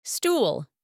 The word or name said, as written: stool